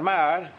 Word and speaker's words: Maher: Father Maher, M-A-H-E-R, they tell me that's the way to pronounce it, I don't know, is that correct?